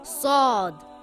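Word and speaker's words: Sad (صاد): The closest letter would be the letter S in English and similar to the letter Sin, but it is pronounced with a heavy sound, Sad. Like the word sword or the word saw, but heavy. It is not pronounced as sword with a W sound.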